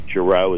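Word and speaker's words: Gerow: A lot of people have a hard time pronouncing my last name. I hear so many pronunciations that I can't even keep track of all of them myself, but it's properly pronounced Giroux. It's G-E-R-O-W.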